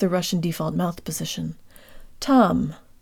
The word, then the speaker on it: там: Там. Там. The te is pronounced with the part of the tongue just behind the tip. That's the portion that makes contact with the roof of the mouth.